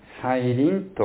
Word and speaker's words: 再臨: 再びという字に望むと書いて再臨と読みます。